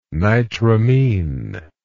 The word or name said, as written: nitramine